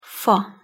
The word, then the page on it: fa: Hungarian